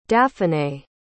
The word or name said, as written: daphanay